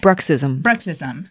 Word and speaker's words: bruxism: is that how you say it? – Bruxism. Grinding and clenching.